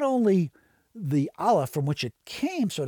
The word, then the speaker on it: Aleph: And the first symbol that they recognized was the symbol for the Hebrew letter A, which is pronounced Aleph, and it was the symbol of the void, that which comes into being from that which is without any being at all.